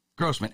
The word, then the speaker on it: Grosman: Grosman, excellent.